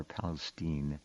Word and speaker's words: Palestine: That's how they pronounce it there, Palestine.